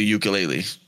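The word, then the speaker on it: Yooka-Laylee: And it's basically Ukulele. What's up, Kakashi? Man, yeah, Have you ever? – Is that a crazy list of games? – And they spell it like weird, right? It's like Yuka. Okay, yeah, here it is. – Yooka. – Lele. – Lalee. Yeah.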